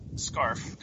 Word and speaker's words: Scharf: Scarf. Come on, say it right. Scarf. – Scarf. Fine, let's go Scarf. Oh, yeah. There's an H in there.